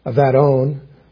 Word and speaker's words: Abaddon: Sheol and Abaddon, and actually the word is pronounced Avadon, lie open before the Lord, how much more the hearts of men.